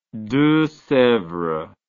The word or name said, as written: deux sevres